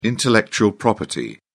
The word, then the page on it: intellectual property: British English